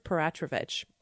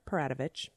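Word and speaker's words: Peratrovich: Elizabeth Paratrovich. Or Paratovich, people I spoke to for this story pronounced it both ways, was born into the Lukhachari clan of the Raven Moiety.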